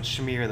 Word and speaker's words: schmear: You have to pronounce it schmear though, because it's a great word.